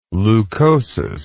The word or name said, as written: leucosis